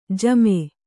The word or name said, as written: ಝಾಮ